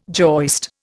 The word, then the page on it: joist: Indian